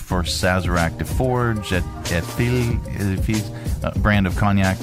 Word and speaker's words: Sazerac: Sazerac.